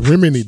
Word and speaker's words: Remini: Yeah, Remini, that's how you pronounce it.